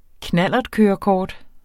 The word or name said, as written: knallertkørekort